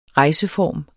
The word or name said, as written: rejseform